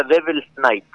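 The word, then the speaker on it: Devil's Nite: La banda se pronuncia Devil's Night, sí. ¿No? Porque está en inglés. – Sí. – Devil's como diablo. – Sí. – Y todo junto, N-I-T-E. Devil's Night-E. N-I-T-E. N-I-T-E.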